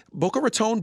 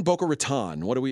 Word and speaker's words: Boca Raton: Boca Raton? Boca Raton. Boca Raton, okay. – That's how the Altacacas pronounce it.